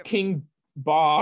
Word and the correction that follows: King Bach: King Bach.